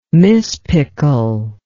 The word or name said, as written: mispickel